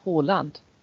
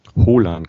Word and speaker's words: Haaland: Erling Haaland. – Haaland, ganz einfach eigentlich, ja, mit genau, OH ganz sozusagen. Einfach. Ja, das genau, das wird wie O ausgesprochen und der Vorname halt auch nicht Erling, sondern Erling.